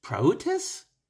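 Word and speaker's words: praotis (πραΰτης): The words he uses are, in Greek, it's praotis, and the other word is epikia.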